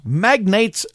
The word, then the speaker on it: Magnate: Charles Magnate's Polka Party is how the name is spelled here, magnately, you know, like he's an oil magnate.